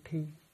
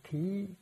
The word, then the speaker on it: thí: Chữ thí này có khi đọc là chữ thi. Thi có nghĩa là làm, nghĩa là thi hành. Như thí có nghĩa là hiến tặng. Ở đây mình đọc là chữ thí.